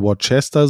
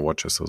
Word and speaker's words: Worcester: Ich glaube, die wird ausgesprochen Worcester Soße, aber ich bin mir nicht sicher. – Ja, Worcester Soße.